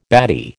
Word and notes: batty: American English

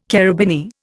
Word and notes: carabineer: Indian

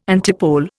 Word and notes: antipole: Indian

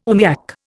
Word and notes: oomiak: Indian

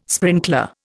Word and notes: sprinkler: Indian

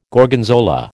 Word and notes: Gorgonzola: American English

